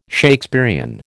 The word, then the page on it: Shakespearian: American English